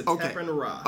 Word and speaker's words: SetepenRa: Yeah, SetepenRa.